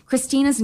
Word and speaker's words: Christina: But we have opted to use the Swedish pronunciation, which is like adding the like Helena and Christina. So if you hear that, then that's what that means.